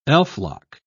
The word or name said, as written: elflock